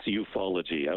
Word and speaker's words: ufology: Okay, am I saying that right? Ufology? – It's ufology, absolutely.